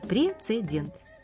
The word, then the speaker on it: прецедент: Слово как пишется, так и произносится. Пишется прецедент и произносится прецедент. Как пишется, так и произносится. Ну что вы. Просто, наверное, кто-то решил для удобства произношения лишнюю букву вставить.